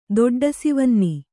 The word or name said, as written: ದೊಡ್ಡಸಿವನ್ನಿ